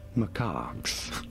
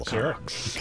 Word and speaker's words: macaque: How do you say it? Macaques. Macaques. Pretty straightforward. Once you know, you do want to stress on the second syllable. – Second syllable. – Macaques.